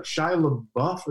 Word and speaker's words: Shia LaBeouf: Like, Shia LaBeouf, am I saying it correctly? – I think you are, yeah.